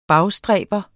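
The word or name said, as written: BAGSTRÆBER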